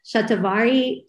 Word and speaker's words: shatavari: And I think last time I spoke to Karri, we also spoke about shatavari. Shatavari is how you say it.